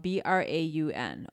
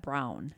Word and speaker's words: Braun: Last name is spelled B R A U N. Okay, down in the Milwaukee area, we pronounce it Braun. Up in Sheboygan, they pronounce that Brown.